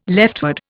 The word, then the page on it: leftward: Indian